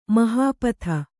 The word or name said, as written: ಮಹೀಪತಿ